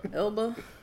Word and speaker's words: Elba: Elba. It sounds how it's spelled.